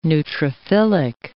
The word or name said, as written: neutrophilic